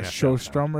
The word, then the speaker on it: Sjöström: It's more of an SH sound. – Yeah, Sjöström or something.